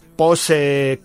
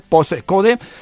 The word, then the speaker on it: Postcode: Así que recordamos, postcode, tal cual, como se escribe, como suena, post con la T, postcode.